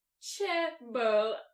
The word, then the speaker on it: chaebol: It's spelled C-H-A-E-B-O-L, and that foolish thing I just said was how the internet pronounced it.